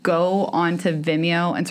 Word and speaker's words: Vimeo: I'm never entirely certain how that I is supposed to be pronounced. – I'm pretty sure it's Vimeo.